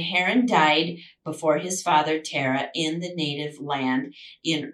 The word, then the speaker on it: Terah: So that's his name, Terah. It's spelled T-E-R-A-H.